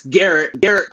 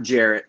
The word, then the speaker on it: Gerrit: It's Gerrit Cole, not Jarrett. Gerrit Cole. – Oh, okay. What did I say? – It's a subtle pronunciation key. No, you said Jarrett, which is – it's just the pronunciation thing.